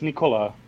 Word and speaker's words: Nikola: It's Nikola. – Nikola. – Yeah, kind of like the Vucevic, how you say it. Like, right. – He's called Nikola Vucevic, and Nikola.